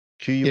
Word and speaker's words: Quibi: Anyway, so the latest entrance into streaming services, is it Quibi? Is that how you pronounce this one? – Quibi. It's short for Quick Bits or Quick Bites or something like that. But yeah, it's people are just calling it Quibi.